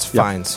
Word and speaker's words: Fiennes: That's how you say it, right? – Yes, Fiennes. – Yep, Fiennes.